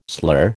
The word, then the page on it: slur: American English